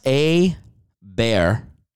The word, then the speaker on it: Aber: First off, it's pronounced A-Bear.